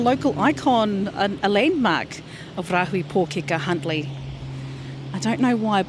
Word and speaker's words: Rāhui Pōkeka: Rāhui Pōkeka.